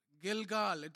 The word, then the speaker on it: Gilgal: Verse 9. Gilgal. Gilgal. That's what it says. That's how it is pronounced.